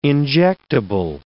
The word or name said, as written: injectable